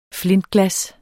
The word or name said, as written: flintglas